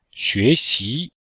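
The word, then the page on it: xuexi: xue2xi2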